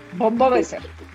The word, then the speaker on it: Wobeser: Hoy tenemos como invitada a Alexa Wobeser. A ver si lo dije bien. Wobeser. – Perfecto. – Ya, es que luego lo acentuó mal.